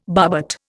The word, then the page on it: barbet: Indian